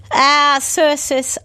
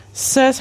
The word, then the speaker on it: Cercis: Circis, Oklahoma. – Circis, how do you spell that? – C-E-R-C-I-S.